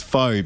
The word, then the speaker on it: pho: It's pho, but pronounced correctly.